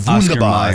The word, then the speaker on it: Wunderbar: Wonder Bar. – That's it's actually like Wunderbar is how you say it.